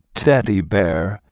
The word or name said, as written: teddy bear